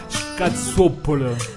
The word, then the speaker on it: scazzuoppolo: Scazzuoppoli, ma non si dice così. – Ah no, scazzuoppolo, capito? – Scazzuoppoli. – Ma sì, fidati che è così.